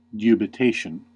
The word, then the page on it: dubitation: American English